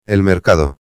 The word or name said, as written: el mercado